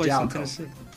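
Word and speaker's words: Jaleco: A-I-C-O-M. And published for the NES by Jellico. Jellico? – I believe it's pronounced Jalco. – Jalco. – I was thinking okay. Jellico. – Because we already covered a Jalco game like my podcast. So I've always said Jalco.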